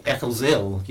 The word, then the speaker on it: Eccles Hill: And my friend Malcolm, who comes from Bradford here, sort of says, no, you don't say Eccles Hill. That's a bit posh. You say Eccles Hill. You don't pronounce the H.